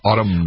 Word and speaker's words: autumn: Kind of like no one puts the N on the end of autumn.